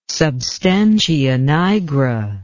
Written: substantia nigra

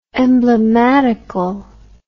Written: emblematicalness

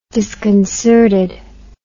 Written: disconcerted

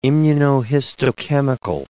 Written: immunohistochemical

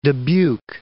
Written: Dubuque

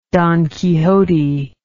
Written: don quixote

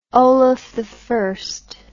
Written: olaf i

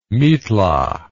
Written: mitla

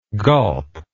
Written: golp

